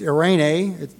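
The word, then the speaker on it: Irene (εἰρήνη): The Greek word looks a lot like the word Irene. It's Irene, but it's spelled E-I-R-E-N-E.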